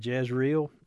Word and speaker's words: Jezreel: And the children's name, when they had, when Gomer had the three children, it was Jezreel. Is it? Am I saying it right? I totally lost. – Yeah. It was names that had judgment attached to them. – Yeah. That's it. Jezreel, and that means to sow and to scatter.